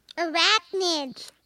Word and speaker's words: arachnids: How do you say that? Arachnids? – Arachnids.